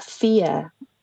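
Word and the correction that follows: Faidh: Fia? – Oh, yeah. – Am I pronouncing it wrong? – Yeah, it's actually Fay, yeah.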